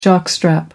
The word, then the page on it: jockstrap: American English